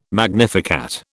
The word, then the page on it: magnificat: British English